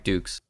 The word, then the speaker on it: Dux: Dukes, Frank Dukes. – Well, I remember that we were pronouncing it wrong the whole time, and then we said Dux. His name is Frank Dukes.